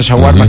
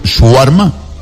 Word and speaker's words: shawarma: ¿Sí sabes que se pronuncia eso? ¿Shawarma? – Shawarma. – No es shawarma. – Otros dicen con la chawarma. Tampoco.